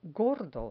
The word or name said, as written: gordo